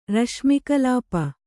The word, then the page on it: ರಶ್ಮಿಕಲಾಪ: Kannada